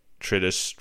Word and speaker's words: treatise: It's like a fetus, but it's treatise.